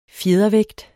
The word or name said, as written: fjedervægt